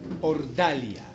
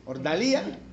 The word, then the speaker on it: Ordalía: Los que toman nota, por favor, allí anote el juicio de Ordalia. Es el título. El juicio de Ordalia. Ordalia. Cuando tiene tilde en la I, ¿cómo se dice? Ordalía. Entonces, el juicio de Ordalía.